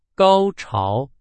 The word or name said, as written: 高潮